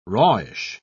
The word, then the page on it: rawish: British English